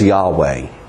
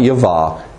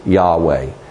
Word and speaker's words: Yahweh: The Revised Standard Version testified in its original preface that the name is Yahweh, not Yavah. It is Yahweh.